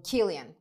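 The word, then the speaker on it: Cillian: Gillian mı okunuyordu? Gillian Murphy mi? Nasıl Hayır. – Okunuyordu? – Killian. – Ha, Killian Murphy.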